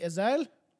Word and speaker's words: Asle: A-S-L-E, that's literally Azale.